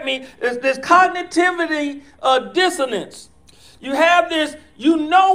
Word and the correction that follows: cognitive dissonance: Did I say it correctly? Cognitive dissonance. Told you she'd get me right.